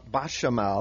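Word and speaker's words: béchamel: The bechamel sauce, is that how you say it? – It's bechamel.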